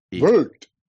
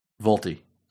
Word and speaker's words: VoLTE: So it's Volt. – Volt. – Volty. – Now let's just go with Volt. – The E is silent?